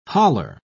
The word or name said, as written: haler